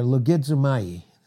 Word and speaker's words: logizomai: The Greek word is logizomai, or logizomai, it's proper pronunciation.